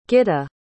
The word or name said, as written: gitta